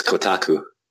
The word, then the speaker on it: Kotaku: Yes, Kotaku.